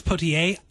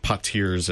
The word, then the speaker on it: Poitiers: I still know how to pronounce Poitiers. I win. – Yeah, Poitiers is how I always say it because I'm just not good at, you know, getting the accent.